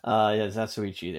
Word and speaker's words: Zatoichi: Zatchtoy? No. – Oh, Zatoichi? – Zatoichi. Is that a wrestler in WWE? – Oh, no, Zatoichi, the blind swordsman. – Doubt. yeah, Zatoichi, there you go.